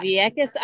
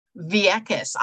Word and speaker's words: Vieques: Vieques Island, Puerto Rico. – Vieques.